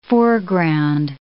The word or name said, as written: foreground